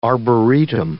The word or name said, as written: arboretum